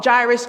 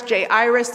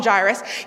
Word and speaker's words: Jairus: You can call him Jairus, J-Iris. There's many ways we've heard this pronounced. I'll call him Jairus.